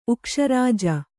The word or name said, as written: ಉಕ್ಷರಾಜ